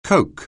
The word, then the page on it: Coke®: anglais